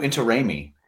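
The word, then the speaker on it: Intiraymi: Inter-I-me. – Close enough. Manu Interraimi. – Into Raimi.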